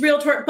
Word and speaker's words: realtor: It's realtor. But I have to think about it before I say it, because I also want to add the third syllable, the realtor.